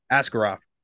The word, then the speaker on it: Askarov: Askarov.